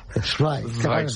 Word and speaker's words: Zweig: Zweig. Zweig. – Es así como se pronuncia en alemán.